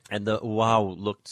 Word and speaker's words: waw: I don't know how to pronounce the Semitic letter, which is spelled W-A-W. – I think it's something like wow.